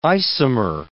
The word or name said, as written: isomer